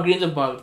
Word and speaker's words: Grindelwald: Ah, no, Grindelwald, pues, porque es alemán.